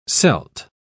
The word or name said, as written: celt